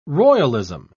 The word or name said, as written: royalism